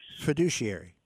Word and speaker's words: fiduciary: a fiduciary, if I'm saying that right, or fiduciary, yeah.